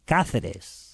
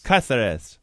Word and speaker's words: Cáceres: Marques de Cáceres. – Cáceres.